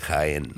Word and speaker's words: Jaén: Ella me dice, la jarra roja de Jaén.